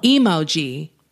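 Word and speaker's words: EmoG: Emoji, though, it's spelled E-M-O capital G. – Oh. – Emoji.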